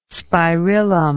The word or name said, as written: spirillum